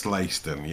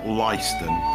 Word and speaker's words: Leiston: It is pronounced Leiston. Yeah, we've looked into it. It's spelt Leiston, but it's pronounced Leiston.